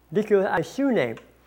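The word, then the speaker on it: Dikaiosune (δικαιοσύνη): Now the word used there for justice in the original language was a word pronounced diakonos. Excuse me. Dikaiosune.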